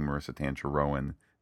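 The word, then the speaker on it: Tancharoen: account, and Marissa Tancheroen, who, by the way, Pete, I know I'm pronouncing Marissa Tancheroen and her name correctly, because she told us that on Twitter back before the show started.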